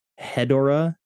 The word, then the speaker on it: Hedorah: Hedorah, it's spelled like Deborah, so maybe it is Hedorah.